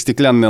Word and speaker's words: стеклянный: Но все-таки запомнить эти случаи иногда нам помогает и произношение, ведь там, где две «н», особенно в случаях «стеклянный», «оловянный», «деревянный», мы как-то все-таки более длинно произносим это. Мы не говорим «стеклянный».